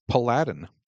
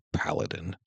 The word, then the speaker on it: paladin: so I pronounced it, of course, paladin, because it must be just like Aladdin, right? So there's my L confession. I eventually figured out it was a paladin.